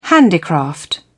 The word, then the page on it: handicraft: British English